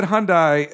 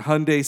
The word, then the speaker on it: Hyundai: When they go to take the thing off Kearney's car, the new H, it's a Hyundai, which I learned is a Hyundai-like Sunday is how it is pronounced, because I'd always said Hyundai as well,